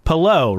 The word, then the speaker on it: Palou: That one's at least easier once someone, I forget who tweeted it out, but it was like, Palou rhymes with hello.